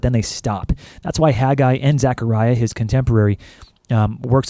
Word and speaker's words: Haggai: Haggai, that's how you say his name.